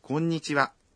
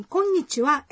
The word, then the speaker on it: konnichiwa: A pronúncia da letra N em konnichiwa é um pouco difícil. – É mesmo. Mas olha, fica mais fácil se você disser konnichiwa em um ritmo de 3 batidas.